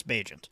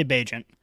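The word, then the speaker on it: Bagent: I think is it pronounced Bajent? I think it's Bajent.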